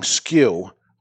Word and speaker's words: SKU: So looking at a brand, which is the best-selling SKU with that company, SKU, pronounced SKU, means stock-keeping unit used by retailers to track inventory.